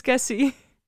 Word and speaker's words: Cassie: Cassie, zeg ik dat goed? Ja, je zegt het goed, Cassie.